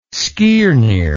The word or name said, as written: skirnir